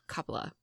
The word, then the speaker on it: Coppola: But apparently Francis Ford Coppola, I can never say it right. – Coppola.